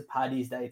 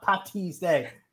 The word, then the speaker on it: Paddy's Day: as it hits into Paddy's Day tomorrow, please, please, please, please, please, don't spell it with a T. – Yes. – Don't spell it with a T. It's Paddy's Day, not Patty's Day.